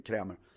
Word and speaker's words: Krämer: Det är ett slott som, tror 1901 eller någonting, som en hovman som heter von Krämer, om du uttalar så, stavas med sån här danskt EA som sitter ihop, Krämer.